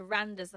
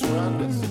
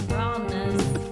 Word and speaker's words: Randers: And also, Leicester travel to Randers. I saw... Or how do you say it? Ronders. He said they don't pronounce the D. – I think it's just Ronders. – Ronders.